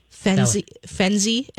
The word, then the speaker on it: Fenzi: Fenzi, F-E-N-Z-I. – F-E-N-Z-I.